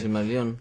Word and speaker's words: Silmarillion: Sí, Silmarillion.